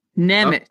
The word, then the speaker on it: Nemeth: Okay, well, first off, it looks like Hockey References pronunciation guide, which I've been consulting lately, has Patrick Nemeth. Nemeth. Oh. – So the TH is pronounced T? – Yeah.